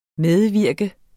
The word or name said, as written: medvirke